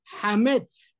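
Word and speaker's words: Hametz (חמץ): Pero cuando hablamos, por ejemplo, de la Het, ¿sí? decimos Hametz. No es una exhalación, es más fuerte, pero no tanto como la Kaf, que cuando uno pronuncia y dice, por ejemplo, ¿qué letra?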